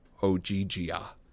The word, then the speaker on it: Ogygia: She pronounced it Ogygia.